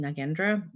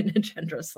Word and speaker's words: Nagendra: My name is Cynthia Nagendra. Also, it's pronounced Nagendra, but I have been using both lately. But my name is Cynthia Nagendra / Nagendra, and I use she, her pronouns.